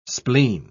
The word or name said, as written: spleen